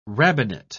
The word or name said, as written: rabbinate